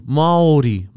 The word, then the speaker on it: Māori: and they're being taught how to correctly say Māori. Just for all of you out there, that it's spelled M-A-O-R-I and there's a tohuto or a macron on the A. Māori. However, this podcaster was teaching them to say Māori.